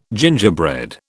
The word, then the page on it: gingerbread: British English